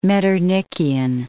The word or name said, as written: Metternichian